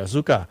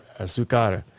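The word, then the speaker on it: Azúcar: Did I pronounce that right? – What? – I wasn't sure I was pronouncing it right. Azuka. – Azucar.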